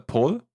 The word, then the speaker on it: Paul: Und äh, ich habe eine französische Arbeitskollegin, die hat mir, ähm, die hat mir gesagt, also äh, Paul im Französischen wird generell Paul ausgesprochen, also wie ähm, mit OO sozusagen, eigentlich Paul.